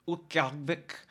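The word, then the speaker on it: Utqiagvik: Like up in Ukiagvik, that's how you say it in Inupiaq.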